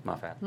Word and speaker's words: Moffat: Moffat.